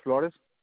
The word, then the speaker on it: Flores: Yes, it's Luis Flores. – All right, spell that last name for me. – FLORES Flores.